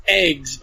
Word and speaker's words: AGWS: uh, a game called Xenosaga uh, where the the a lot of the characters, um, some of them have it's it's pronounced eggs, but it's like A G W S.